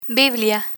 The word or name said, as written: biblia